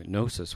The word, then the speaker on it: gnosis: In fact, the word knowledge, is the word Gnostic, comes from the Greek word to know, gnosis with the silent G.